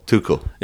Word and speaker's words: Tuchel: Is it Tuchel or Tuchel? – Tuchel. – It's Tuchel.